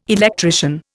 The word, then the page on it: electrician: Indian